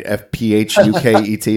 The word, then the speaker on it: Phuket: Phuket, isn't that how you say it? Yeah. It's very strange. – Yeah. Exactly how it should be said.